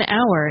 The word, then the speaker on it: hour: So to answer Matt's question, an hour is correct, because hour starts with a vowel sound.